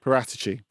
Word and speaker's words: Paratici: How am I saying that? – Paratici. – Paratici. – Yeah.